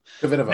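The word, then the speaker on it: Kvitová: and Petra Kvitova, or however you pronounce her name. – Kvitova.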